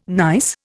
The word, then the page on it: gneiss: Indian